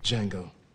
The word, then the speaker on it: Django: Django. Can you spell it? – D-J-A-N-G-O. The Dia Silent.